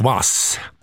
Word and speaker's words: hvass: Det uttalas gvit och vass.